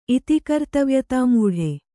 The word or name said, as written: ಇತಿಕರ್ತವ್ಯತಾಮೂಢೆ